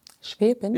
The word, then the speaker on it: Schwäbin: Habe ich es falsch gesagt? – Schwäbin? Ja, nein. – Schwäb. – Okay, ja, vielleicht bin ich zu schwäbisch, aber schwäbisch. – Ja, okay, dann dabei. – Ich dachte Schweben. – Okay, Schwäbin.